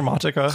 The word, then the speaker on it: Traumatica: So, okay, here's something in a nutshell what we can expect from Traumatica, or how do you pronounce it in German? – Yeah, that's absolutely the same. It's just Traumatica. Okay. So, Traumatica. – Traumatica, yeah, works.